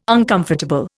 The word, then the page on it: uncomfortable: Indian